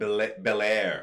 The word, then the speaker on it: Bel-Air: You were talking about Bel-Air. – Yeah. So that comes out in 2019. – Is that how it's said? – Yeah. That's how you pronounce it.